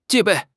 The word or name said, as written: 戒备